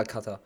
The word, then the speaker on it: Kata: Ich habe gerade kurz überlegt, wie es richtig ausgesprochen wird, aber Kata.